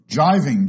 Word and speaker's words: gyving: You can drop it, so it's G-R-O-S-Z as well. Jiving, G-Y-V-I-N-G, is to shackle. Gigots, G-I-G-O-T-S, is a leg of lamb. Guying, G-U-Y-I-N-G, is to ridicule.